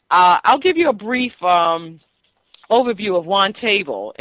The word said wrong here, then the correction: Wantable: Am I saying that correctly? – It's Wantable, yes. – Wantable, okay. – Yeah, Wantable, okay.